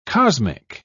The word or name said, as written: cosmic